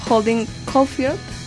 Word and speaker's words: Holden Caulfield: Holden Caulfield, creo que sí. Se pronuncia así,